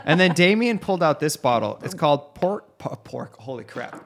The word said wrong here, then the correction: Park: Pork? – Park. – It's a four-letter word. – It's a four-letter and I butchered it. Park cognac. – One syllable.